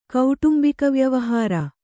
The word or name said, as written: ಕೌಟುಂಬಿಕವ್ಯವಹಾರ